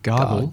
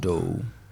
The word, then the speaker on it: Gardot: Melody Gardot, Gardot, Gardel? – Gardot.